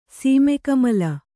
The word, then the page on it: ಸೀಮೆಕಮಲ: Kannada